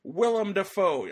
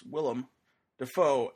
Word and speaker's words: Willem Dafoe: Willem Dafoe, that's how you say his name, folks, Willem Dafoe.